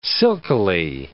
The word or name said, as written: silkily